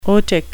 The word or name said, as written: otic